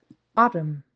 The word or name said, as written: autumn